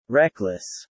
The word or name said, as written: reckless